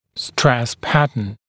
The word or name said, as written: stress pattern